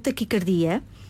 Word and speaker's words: taquicardia: Esta palavra pronuncia-se com o A fechado, taquicardia.